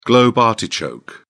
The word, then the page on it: globe artichoke: British English